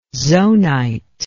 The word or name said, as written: zonite